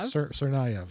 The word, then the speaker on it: Tsarnaev: I'm not going to say his name correctly, but Jokhar Tsarnaev. – Tsarnaev.